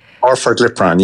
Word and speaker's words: orforglipron: Orforglipron, if I'm saying that correctly? – Orforglipron, yes.